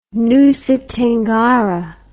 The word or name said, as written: nusa tenggara